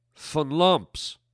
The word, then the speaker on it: Funlumps: It's German? It's pronounced what? Funlumps. Okay, well, it reads Larry Funlumps. It's Funlumps? Okay, all right, his ancestry must be German.